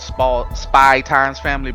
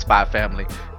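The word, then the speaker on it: Spy × Family: So kind of like the way they draw it, spy times family, but it's pronounced spy family.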